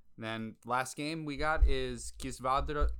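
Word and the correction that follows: Kisvárda: Kisvarda. Wow, butchered that the first time.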